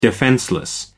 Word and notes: defenceless: American English

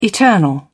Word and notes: eternal: British English

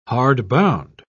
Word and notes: hardbound: British English

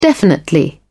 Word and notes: definitely: British English

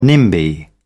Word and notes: nimby: British English

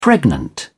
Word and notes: pregnant: British English

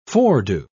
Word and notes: foredo: British English

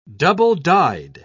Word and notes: double-dyed: British English

